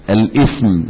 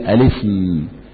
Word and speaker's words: الاسم: لا، كثير من الناس يكتبون الهمزة على ويكتبون الهمزة على الألف ويقولون الاسم، لا، هي ليست الاسم، هي الاسم، ألف لم ألف بدون همزة سين ميم، تمام، لأنها همزة إيه؟ همزة وصل، تنطق في بداية الكلام وتسقط في الدرج في الوصل. يعني ابن وابنة، أيم الله، أيمن الله، تمام، امرأة، هذه الأسماء اثنان، اثنتان، هذه الأسماء تبدأ بهمزة وصل، تمام، بهمزة وصل، وهكذا.